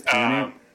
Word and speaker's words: Punnett: Do you pronounce it Punnett? I haven't said that word out loud in so long. Or is it Punnett? – I think it's Punnett. – It's Punnett.